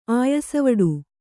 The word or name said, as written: ಆಯಸವಡು